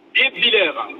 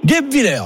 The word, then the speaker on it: Guebwiller: C'est quoi, c'est Guebwiller ? – Guebwiller. – Guebwiller, voilà, j'ai failli bien le dire.